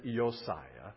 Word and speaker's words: Josiah: I learned this over the years, that the J in Hebrew is sounded like a Y. And so you pronounce it almost like J in Spanish is H, right? In Hebrew, the J is like a Y. Like Josiah, we would say in English, is Yosiah. And so tonight, what we're going to do, shall we do it together?